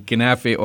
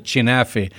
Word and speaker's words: knafeh: And you cannot compete with the quality of the knafeh, or for some people they pronounce it chinafeh. If you are if you are from certain villages, yeah. – you refer to it as chinafeh. I'm a Jerusalemite, so we call it knafeh or knafeh nablusiyeh.